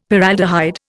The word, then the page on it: Paraldehyde: Indian